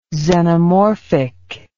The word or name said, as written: xenomorphic